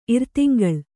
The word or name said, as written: ಇರ್ತಿಂಗಳ್